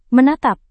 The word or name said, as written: menatap